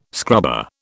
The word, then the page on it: scrubber: British English